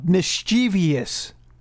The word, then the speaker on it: mischievous: It's pronounced mischievous. I know we always do this, but like it is definitively pronounced mischievous. Every few months we have to have this convo, but just to review, do you still think that mischievous is a more mischievous way of saying the word? – Yes, I do.